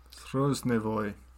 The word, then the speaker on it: Storozhevoy: So Valery was serving at the time on a battleship, kind of like a destroyer, called the Storozhevoy, which means the century. – Storozhevoy. – You pronounced it better than me.